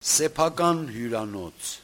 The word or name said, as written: sepakanhyuranots